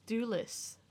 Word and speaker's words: Thewlis: David Thewells. Is that how you say his name? – I thought it was Thewlis. – Thewlis? – I don't know. – That sounds right. – It sounds British. – It's T-H-E-W-L-I-S.